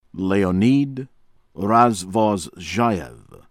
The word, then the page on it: Leonid Razvozzheyev: American English